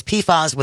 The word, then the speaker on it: PFAS: Google says, by the way, it's PFAS with a short A.